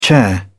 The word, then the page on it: chair: British English